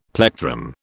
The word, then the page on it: plectrum: British English